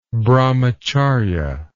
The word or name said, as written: brahmacharya